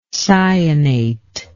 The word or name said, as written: cyanate